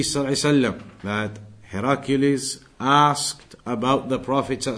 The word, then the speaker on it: Heraclius: In Arabic known as Heracl, and in English pronounced as Heracles or Heraclius, that Caesar of Rome.